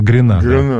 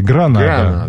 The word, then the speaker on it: Гранада: замечательный советский поэт Михаил Светлов написал знаменитое стихотворение «Гренада». Ну, собственно, правильно говорить «Гранада». Да.